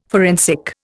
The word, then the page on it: forensic: Indian